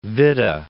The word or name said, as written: Witte